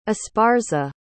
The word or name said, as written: esparza